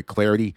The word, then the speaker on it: Clardy: And, of course, you can also follow me on Twitter as well, at Troy Clarity. The last name is spelled C-L-A-R-D-Y.